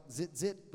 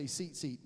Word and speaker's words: tzitzit: It's spelled T-Z-I-T-Z-I-T. It sounds like zit-zit, but it's tzitzit.